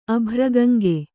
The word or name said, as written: ಅಭ್ರಗಂಗೆ